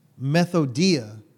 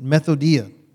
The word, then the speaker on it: methodia: So this methodia, that's how you say it, methodia.